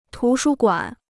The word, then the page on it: 图书馆: tú shū guǎn